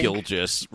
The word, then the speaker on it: Gilgeous: It's Gilgeous, I think. – Gilgeous, right? Or Gilgeous? It's like an igneous rock.